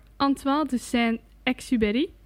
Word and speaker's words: Antoine de Saint-Exupéry: En De Kleine Prins is geschreven door Antoine de Saint-Exupéry.